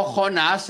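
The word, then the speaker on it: Joná (יונה): Lo que pasa es que en hebreo Jonás se, escribe Joná o se pronuncia Joná,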